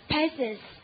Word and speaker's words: patience: 你看它发音，peasants 哈。陪生死的感觉，对不对？陪你从生到死，还不够有耐心吗？Patience，好，陪生死，看到了吧？